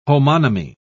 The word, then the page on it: homonymy: British English